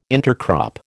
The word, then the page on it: intercrop: American English